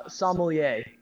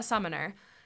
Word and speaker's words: sommelier: Sommelier. – Yes, a summoner.